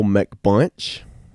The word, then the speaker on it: McBunch: My name is John Michael McBunch. Last name is spelled M-C-B-U-N-C-H.